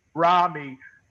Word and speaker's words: Ramy: And to be on this phone call with Wanda and Dan and Maya and Rami and I'm saying that correctly, right? – Rami. – Yeah.